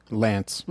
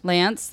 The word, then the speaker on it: L'Anse: Lance. – Lance, that's mm-hmm.